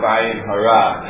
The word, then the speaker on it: Ayin Hara: And that is the topic of Ayin Hara, or Ayin Hara, as it's commonly pronounced. And Ayin Hara, I will allow myself the luxury of mispronouncing it in the same way that everyone else mispronounces it. If you want to be a stickler for the language, it's Ayin Hara. Okay, but everyone says Ayin Hara, Gli Ayin Hara, Ken Ayin Hara, whatever it is, so we'll just say it that way.